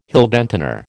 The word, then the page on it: Heldentenor: American English